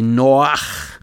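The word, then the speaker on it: Noach (נח): And so uh, Moses is really Moshe, and Jonah is really Yonah and Noah is really Noach.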